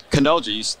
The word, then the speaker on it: Canoja: Canoja, how do you say it? – Canoja, you got it perfect.